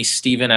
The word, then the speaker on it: Stephen: My parents named me Stephen after the first martyr. So my name is spelled S-T-E-P-H-E-N, not with a V.